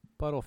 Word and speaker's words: butyl: Yeah. Buttle fuel. I pronounce that buttle fuel.